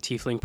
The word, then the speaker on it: tiefling: What I would like to note is they actually do now canonically talk about the tiefling pronunciation. So in this little blurb at the beginning here, it says, a tiefling, open bracket, pronounced... Tiefling, close bracket, is linked by blood to a devil, a demon, or a Yugloth, or some other fiend.